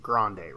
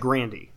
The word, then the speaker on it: Grande: Well, you know they don't actually pronounce the last name Grande, right? – Right. – It's like Grandy.